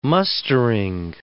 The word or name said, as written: mustering